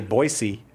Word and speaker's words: Boise: I just recently learned you say Boise and not Boise.